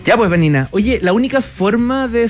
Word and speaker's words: Vanina: Sí, les dejo mis redes sociales, Vanina Jaime. Jaime, como el nombre se escribe, Vanina con B corta, oficial. Por Instagram me pueden encontrar. También en LinkedIn, Vanina Jaime,